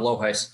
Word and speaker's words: Lohuis: No, it's Tom Loheis.